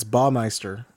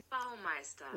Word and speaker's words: Baumeister: There is no L. It's Baumeister. – Baumeister. Should we get Google on this? – You should probably get Google on it, but I'm pretty sure I'm pronouncing it a little off, but I'm pretty sure it's more Baumeister. – You're right. Baumeister. Well, that's it in German.